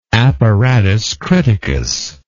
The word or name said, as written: apparatus criticus